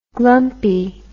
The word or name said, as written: glumpiness